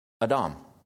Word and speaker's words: Edom: Mount Seir is Edom, and that's how you pronounce it. Adam. And blood is Adam. Well, it's different letters in Hebrew, but they're pronounced the same.